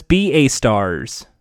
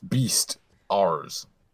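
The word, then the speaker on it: Beastars: So no, hey, if throw your beast stars fan art our way. – Why do you say it like that? Why do you beast stars? – Because B A stars. – No, it's beast stars.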